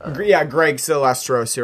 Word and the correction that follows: Sestero: Um, Sestero. – Sestero, yeah.